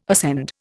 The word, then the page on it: ascend: Indian